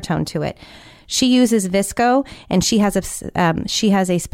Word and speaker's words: VSCO: As far as filters are concerned, there's an app called VSCO, which is pronounced Visco.